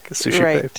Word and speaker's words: Suscipe: If you look at it, it doesn't look like Sushipe, but it's a Latin word that means receive, and it's spelled Suscipe.